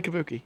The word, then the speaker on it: kabuki: It's Kabuki, right? – I would say Kabuki.